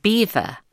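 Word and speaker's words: Belvoir: The name means beautiful view in French, and the locals, who no doubt took a dim view of this un-English name, pronounced it Beaver, which is how it has been known ever since.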